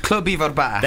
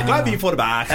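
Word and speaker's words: Clwb Ifor Bach: Club Ivor Bach. – There you go. Club Ivor Bach.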